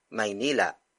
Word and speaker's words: Maynila: Maynila. Maynila. Maynila is spelled as capital M-A-Y-N-I-L-A.